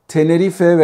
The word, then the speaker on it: Tenerife: Tenerife veya Tenerif kimin söylediğine bağlı olarak e, öyle telaffuz ediliyor.